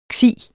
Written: ksi

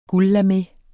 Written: guldlame

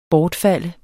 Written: BORTFALDER

